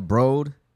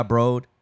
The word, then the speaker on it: Broad: It's pronounced B-R-O-H-D. – Oh, okay. – So it's B-R-O-H-D? – It was named after philanthropist Eli Broad.